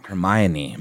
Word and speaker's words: Hermione: Catherine Snow says, "Is it pronounced Hermione Granger from Harry Potter?" – Hermione, my man. Hermione.